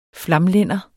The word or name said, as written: flamlænder